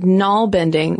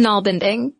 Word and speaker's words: nalbinding: And I gotta tell you, Caroline, side note, when I first read the word nalbinding, which listeners is spelled N-A-L-B-I-N-D-I-N-G, I immediately thought of narwhals.